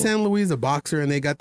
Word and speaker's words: San Luis: San Luis, Arizona comes in at 7. – Actually, you're saying that wrong. It's San Luis, and that's on the border.